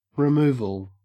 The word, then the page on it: removal: Australian English